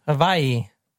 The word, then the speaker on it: Hawaii: Hawaii. – And that's how you say it. Hawaii. Like something like, yeah. – With a little bit of a V? – Yes.